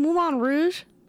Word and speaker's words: Moulin Rouge: Wait, you mean Moulin Rouge? – Moulin Rouge, that's what I mean. Okay. Is that how you're supposed to pronounce yes. – it? – Yes.